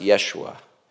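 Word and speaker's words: Yeshua (ישוע): In Hebrew, that's pronounced Yeshua, which means Yahweh is salvation.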